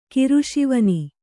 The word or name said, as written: ಕಿರುಶಿವನಿ